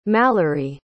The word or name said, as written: malory